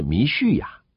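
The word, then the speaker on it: 弥 叙 亚 (מָשִׁיחַ): 膏抹的希伯来文是弥赛亚，我们称弥赛亚，其实原文的发音是弥叙亚。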